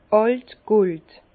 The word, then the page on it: àlt gold: Bas-Rhin; Herrlisheim